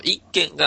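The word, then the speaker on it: 一間: 太閤検地の時代はですね、一軒、まあ、一つの間、間と書いて一軒と読むんですけど、一軒が六尺三寸だったんですね。 – 六尺三寸ということは、 1.9 メートル。 – 1.910 ミリですね。 – うん、あ、 1910 ミリなんで 1.9 メートルぐらいですね。 – 1.9 メートル。はい。 – はい。で、江戸時代になるとですね、その一軒の解釈が 6 尺になります。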